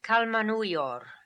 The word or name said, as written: Kalmanuior